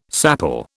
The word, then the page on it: sapele: British English